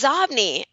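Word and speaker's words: Xobni: It's Zobny. And believe it or not, it was originally pronounced Zobny, but Bill Gates pronounced it Zobny. So the company changed the pronunciation of its own name because Bill Gates pronounced it Zobny. Okay, so now we know it's pronounced Zobny.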